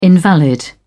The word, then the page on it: invalid: British English